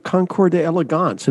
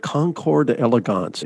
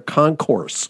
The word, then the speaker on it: Concours d'Elegance: A lot of guys call it a concourse. That's because maybe they're not quite so well bred as to understand how it would be pronounced.